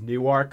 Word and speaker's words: Newark: It's they actually pronounce it as Newark because they want to differentiate themselves from the Newark up here.